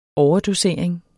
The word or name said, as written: overdosering